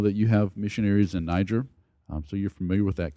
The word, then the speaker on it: Niger: The two other places that we're thinking about are Galmi, Niger, or Niger, if you want to do the French pronunciation, and Kajabe, Kenya.